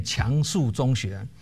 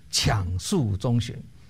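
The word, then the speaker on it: 强恕中学: 台北市有一间中学叫做，大家都念强树中学，其实正确的发音是抢树中学，因为这个抢是勉强的抢，是勉强自己的意思啊。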